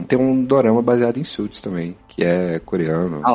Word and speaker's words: Suits: Eu já assisti Suits, que muita gente chama de Suits. Aí uma vez uma mulher me corrigiu, falou que na verdade é Suits. É, é, a pronúncia certa é Suits.